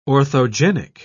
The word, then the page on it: orthogenic: British English